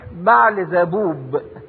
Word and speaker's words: بعل زبوب: كلمة بعل زبول اصلها او نطقها الصحيح بعل زبوب.